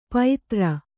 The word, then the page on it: ಪೈತ್ರ: Kannada